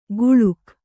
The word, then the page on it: ಗುಳುಕ್: guḷuk; kannada